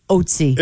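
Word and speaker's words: Ötzi: Okay, so let me read this, because I thought you'd go like, common knowledge, it's pronounced Otzi. – It's Otzi?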